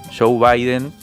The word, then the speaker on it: Joe Biden: Ahora Alberto Fernández y su ministro de Economía Martín Guzmán esperan con ansiedad la asunción de Joe Biden que tendrá lugar el próximo 20 de enero.